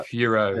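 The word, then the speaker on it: Fiorot: How do you say her last name? Foret? – She's Foret? – Furo. Yeah. She's French.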